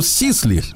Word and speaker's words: Сисли: Ну, сейчас бы сказали, что он фамилия у него Сисли. – Сисли, Он ну, пишется да. Сислей, угу.